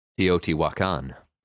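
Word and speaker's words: Teotihuacán: Teotihuacan.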